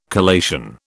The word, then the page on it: collation: British English